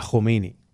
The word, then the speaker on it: Khomeini: So how would you do it in Farsi? – It's Ayatollah Ruhollah Khomeini.